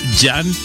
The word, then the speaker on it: Jon: Con el señor es Jan Van Jovi. Su nombre es Jan. No es John, no es John. Es Jan. Aunque se escribe J-O-N, no trae H, esto, ¿no? Pero se pronuncia Jan.